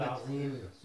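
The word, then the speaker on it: Azim (عظيم): Subhan rabbiyal azim. Subhan rabbiyal azim. – Z ile değil yalnız. Azim peltektir. Z, belki sen tam telaffuz edemiyorsun ama Z söyle. Azim.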